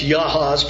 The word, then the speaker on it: Jahaz: came out to meet us in battle at Jahaz. It'd be pronounced Yahaz, probably. The J's sound like Y's in their language.